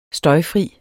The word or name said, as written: støjfri